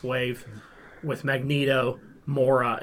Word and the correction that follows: Moira: Did I say her name wrong? – Oh, I thought you were saying that was the Build-A-Figure. How do you say her name? – Moira. – Moira. Okay.